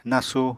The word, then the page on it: nasu: Apatani